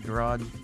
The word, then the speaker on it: garage: It's not a hard G at the end, garage.